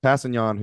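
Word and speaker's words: Kpassagnon: So Passignon, who name starts with a K, but the K is silent, had played 43% of the snaps.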